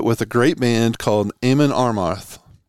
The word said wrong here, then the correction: Amon Amarth: I God, I'm saying that wrong. It's Amon Amarth, um, and former Arizona State head coach Tate Green busted my balls one day.